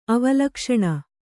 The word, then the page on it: ಅವಲಕ್ಷಣ: avalakṣaṇa